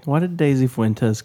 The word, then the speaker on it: Fuentes: Just sounds funny when you say I think it's properly pronounced Fuentes. – Fuentes? – It's Fuentes. I believe that's the proper Hispanic...